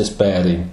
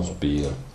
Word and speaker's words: Speer: Se Speer, si legge Speer in Germania, non Speer.